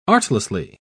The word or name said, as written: artlessly